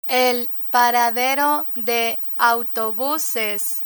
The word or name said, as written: el paradero de autobuses